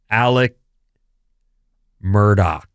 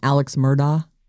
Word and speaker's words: Alex Murdaugh: But okay, I guess that's the name that I just read to you is a big one, Alec Murdaugh. – Everyone. And people were calling him Alex Murdaugh. – Well, it is spelt Alex. So, oh. – A-L-E-X, Alex. But it's Alec Murdoch.